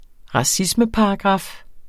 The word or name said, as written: racismeparagraf